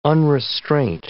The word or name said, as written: unrestraint